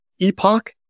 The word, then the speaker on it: Epoch: One, there's an AI research group that studies trends in AI called EPOCH, spelled like EPIC, EPOCH.